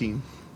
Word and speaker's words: Bottin: I want to say Bottin because I've only read his name. – But it's Bottin.